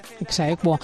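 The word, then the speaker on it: ex aequo: Atsequo, se dice así, ¿no? Junto con... ¿Se uh-huh dice así? – Exequo.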